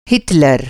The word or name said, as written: हिटलर